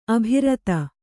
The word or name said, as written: ಅಭಿರತ